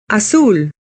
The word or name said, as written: blue